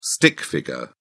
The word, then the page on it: stick figure: British English